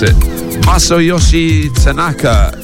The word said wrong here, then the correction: Takanaka: Takanaka, I should have practiced that beforehand.